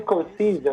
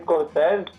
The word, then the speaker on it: Scorsese: Quer dizer, nos Estados Unidos eles pronunciam Scorsese, agora a gente se acostumou a dizer Scorsese.